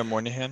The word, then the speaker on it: Moynihan: Am I saying that correctly? – Yeah, Moynihan.